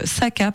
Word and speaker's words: Sakka: Et tout est publié chez Casterman dans leur collection Shaka Auteur. Donc, Saka, pardon. Saka Auteur, un peu comme un sac à hauteur, sauf que ça s'écrit S-A-K-K-A.